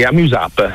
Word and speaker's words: Amuse App: È Amuse App, in modo che si possa leggere sia all'italiana che all'inglese, cioè come funziona? – Sì, Amuse App, okay. – Noi lo pronunciamo così.